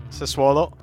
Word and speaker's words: Sassuolo: Sassuolo. Is that how you pronounce it? Sassuolo? – Yeah, Sassuolo, yeah.